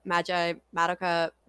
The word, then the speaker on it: Madoka: and that's how they say her name the entire time, Madoka. So I guess we were wrong, even though it feels like an anglicization.